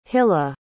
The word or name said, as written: hilla